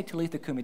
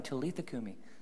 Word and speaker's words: Talitha kumi: So when you say that, just say Talitha kumi. Just say that right now, Talitha kumi. All right, two people did that. Just try it again. Talitha kumi.